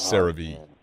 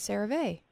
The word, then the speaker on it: CeraVe: Is CeraVe a brand of shampoo, moisturizer, or shoes? – It's CeraVe. – CeraVe. I thought it was always CeraVe.